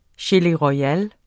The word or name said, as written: royale